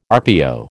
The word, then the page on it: RPO: American English